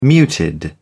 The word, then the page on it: muted: British English